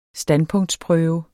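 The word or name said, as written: standpunktsprøve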